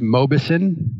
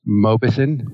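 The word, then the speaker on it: Mauboussin: My last name is pronounced Mobison. Mobison. Okay.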